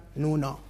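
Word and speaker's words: Nuno (布): Este símbolo, en japonés, se pronuncia Nuno, ¿vale? Nuno en japonés significa tela, ¿vale? – Tela.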